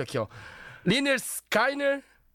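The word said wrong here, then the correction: Lynyrd Skynyrd: Leonard Skinner.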